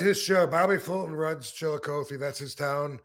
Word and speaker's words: Chillicothe: Chillicothe. – Chillicothe, you got it. Yeah, Chillicothe, Ohio.